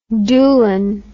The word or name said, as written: doolan